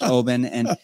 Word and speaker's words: Oban: Some people may say Oban, or but I think it's pronounced Oban.